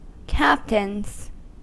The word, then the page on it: captains: American English